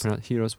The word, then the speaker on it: gyros: Gyros. Well, that's the official pronunciation. I'm trying to Americanize it here.